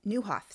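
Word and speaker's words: Nieuwhof: I heard a quote the other day by Kerry Nywolf. Newhoff. Sorry, I always say his name wrong. If you saw how it's spelled, you would say it wrong too.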